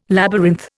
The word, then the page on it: labyrinth: Indian